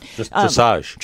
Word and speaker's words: dressage: So I was wondering about this because I started to watch some of the dressage? I don't know how to pronounce it. – Dressage. – Dressage, thank you, event.